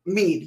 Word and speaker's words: Meade: Sorry, is that what I've been pronouncing it wrong? – Yeah, it's Fort Meade. – Like, I don't know these words. – M-E-A-D, right? – M-E-A-D-E. – Yeah, Meade, Fort Meade.